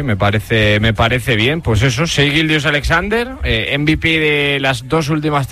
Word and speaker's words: Shai Gilgeous-Alexander: Shai Gilgeous-Alexander. – Shai Gilgeous-Alexander.